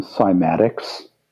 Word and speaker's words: cymatics: Are you familiar with the term cymatics? You heard of cymatics? – I'm not sure. You might have to explain it to me. – Okay. It's spelled C-Y-M-A-T-I-C-S. – Cymatics.